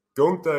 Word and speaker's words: Gunther: How do we pronounce it? – Gunther is correct.